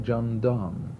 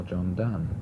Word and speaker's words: Donne: in terms of the way that he wrote about love, someone like John Donne, or John Donne, depending on whether you prefer Don or Dunn as the pronunciation of D-O-N-N-E.